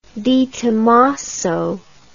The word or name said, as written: detomaso